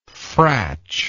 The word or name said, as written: fratchy